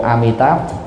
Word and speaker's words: Amitabha: Còn nếu mà muốn đọc sát thì chúng ta đọc là Nam Mô Amitabha Buddha.